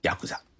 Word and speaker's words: ヤクザ: 啊，用日语来念的话就念成ヤクザ。也有一种说法是比较唯美一些，在过去日本的乡村啊有一个仲裁机构叫役座，这个役呢是劳役的役，座位的座。从夫妻吵架到邻里纠纷，都有这个役座来做仲裁。而役座呢在日语当中的念法就念作ヤクザ。